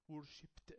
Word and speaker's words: worshipped: Worshipped. أنا قلت worshipped. لا قلت worshipped. Worshipped. إن البي الكلمة اللي بعديها لو إيدي بتتنطق تي.